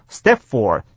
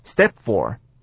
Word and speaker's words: step four: 再来呢，就是 Jennifer 所说的第二段话里面啊，最后两个字 step four， step 的 p 因为后面是 f 的缘故，所以不发音，我们整天做 step four， step four。